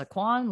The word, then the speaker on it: Kwan: My name is Melissa Kwan, last name is spelled K-W-A-N.